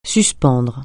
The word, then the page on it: suspendre: French